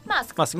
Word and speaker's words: Musk: En marzo de este año, el Boletín Oficial de la República Argentina publicó que Elon Musk, Elon Musk, ¿cómo se dice? – Musk. – Musk, Elon Musk, había anotado a esta empresa bajo eh, el nombre Starlink Argentina SRL.